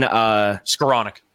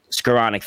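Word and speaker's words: Skowronek: Ben Skoronek. – Skoronek, thank you.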